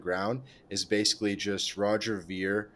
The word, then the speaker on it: Ver: And it's basically about Roger Ver, however you pronounce it. – Ver, yeah.